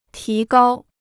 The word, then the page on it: 提高: tí gāo